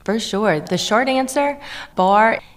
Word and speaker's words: barre: I know some people pronounce it barre, but it's actually pronounced bar, but you guys corrected us before we started.